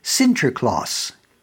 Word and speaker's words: Sinterklaas: And Washington Irving, he had a tremendous imagination, included a dream sequence involving a character by the name of Saint Nicholas, whose name in Dutch is pronounced Sinterklaas.